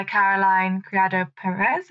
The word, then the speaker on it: Caroline Criado Perez: For me, it'd be Invisible Women by Caroline Criado Perez, if I'm pronouncing her name properly. – Yeah, that's right, yeah.